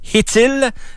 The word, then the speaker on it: Chytil: Lafrenière a patiné au camp d'entraînement avec Philippe Hittil. Ça s'écrit C-H-Y-T-I-L. Faut prononcer Hittil. – Ouais, c'est un CH aspiré, attention.